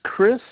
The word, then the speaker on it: Kris: It would be Chris with a K, Molina.